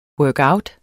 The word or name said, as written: workout